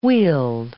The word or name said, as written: wield